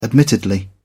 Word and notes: admittedly: British English

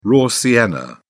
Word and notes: raw sienna: British English

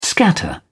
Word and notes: scatter: British English

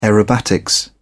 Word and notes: aerobatics: British English

